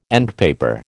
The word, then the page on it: endpaper: American English